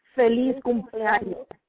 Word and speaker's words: Feliz cumpleaños: How do you say it in Spanish? – Feliz cumpleaños. – Oh, yeah. Feliz cumpleaños, mommy.